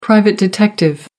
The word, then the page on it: private investigator: British English